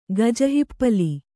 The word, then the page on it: ಗಜಹಿಪ್ಪಲಿ: gaja hippali